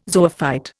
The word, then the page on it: zoophyte: Indian